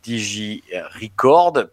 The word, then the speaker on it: DigiRecord: Notre suite d'outils préférés, la digitale, vient euh, de lancer son, euh, son équivalent avec euh, DigiRecord, pour bien prononcer à la française. Euh, j'assume plus mon accent, voilà. Digirecord.